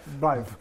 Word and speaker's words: Blyth: Pot să spun, am câteva reacții deja la traducerea lui Alistair Ian Blyth, așa se pronunță, parcă, da? – Blyth.